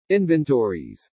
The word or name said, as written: inventories